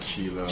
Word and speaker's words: Shelagh: under like Shelagh. Shelagh Brown.